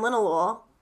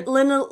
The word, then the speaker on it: linalool: And so, if you're looking for something relaxing, as far as cannabis strains go, look for something high in linalool. – Totally. I'm looking at, yeah, there's how do you say that again? – Linalool.